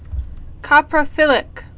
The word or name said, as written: coprophilic